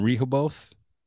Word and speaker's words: Rehoboth: The towns that bind this little triangle, this little 200-square-mile triangle, are Abington, Freetown, and Rehoboth. I'm probably not pronouncing that right. – You nailed it.